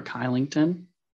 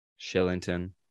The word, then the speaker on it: Kylington: I believe Oliver Kylington. – Shillington, but I'll let you pass.